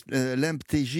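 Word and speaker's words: Lemptégy: Juste, l'MTJ, il faut savoir, ça s'écrit L-E-M-P-T-E-G-Y. – Exactement. – D'accord. Bon. – Ça sonne un peu comme l'MTJ.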